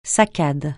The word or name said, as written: saccade